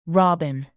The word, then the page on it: robin: bird